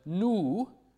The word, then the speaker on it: nu: This nu is our oldest, is very clearly pronounced with that back vowel, u, in Old English.